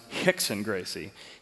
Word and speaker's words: Rickson Gracie: One of the most famous Brazilian jiu-jitsu fighters is not Royce Gracie, but his brother, which is pronounced Hoist Gracie, but Rickson Gracie, pronounced Hickson Gracie.